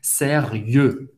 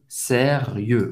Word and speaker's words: sérieux: Sérieux. Sérieux. O X não se pronuncia. E o em francês tem o som do E bem fechado. Faz a boca do O e fala E. E. Sérieux.